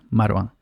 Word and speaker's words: Marwan: Yo le digo Marwan, pero se pronuncia Marwan.